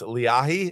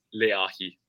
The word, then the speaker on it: Leahi: That's and also I apologize for pronouncing it Lehi. It's Leahy. Is that the correct pronunciation? – Yeah, you guys can go with whatever. It's a Hawaiian word here. It's Leahy.